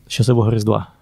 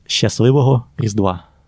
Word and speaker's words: Щасливого Різдва: And how do you say Merry Christmas in Ukraine? – Щасливого Різдва. – One more time? – Щасливого Різдва.